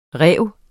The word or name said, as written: rive